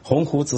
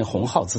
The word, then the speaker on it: 鸿鹄: 我们正在讲北大的沉沦的时候，北大的校长就出来做了一个证明，以他读错一个非常常见的成语来做一个证明，鸿鹄之志读成鸿号之志，然后号召学生立鸿号志，说成了天下的笑话。